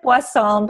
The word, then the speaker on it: Boisson: You did a perfect job. It was it's Elizabeth Boisson, but it was perfect when you pronounced it.